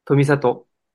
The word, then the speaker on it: 富里: 富里。でしょ？僕で言うんだったら多分、富里って言うよ。イントネーション的には。